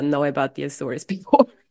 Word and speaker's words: Açores: And I remember that you went to Açores, les Açores. I don't know how to say this. – Azores. – Yeah, Açores. – Azores, Azores, yep.